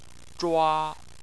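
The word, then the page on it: zhua: tone 1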